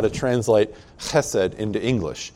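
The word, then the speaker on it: chesed (חסד): If you're going to pronounce it properly in Hebrew, you've got to go, you know, chesed.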